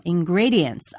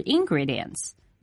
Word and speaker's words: ingredients: 我们要注意一下这个单词的重读音节是在第二个音节上 ,ingredients, 而不是 ingredients。